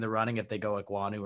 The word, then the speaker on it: Ekwonu: I just say Ekwonu, but I mean. – Okay, yeah. He was beating Ekwonu on some plays.